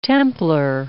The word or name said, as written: Templar